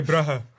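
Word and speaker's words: Brugge: I'm from Bruges, right? And there's two G's in there, so we say bruh.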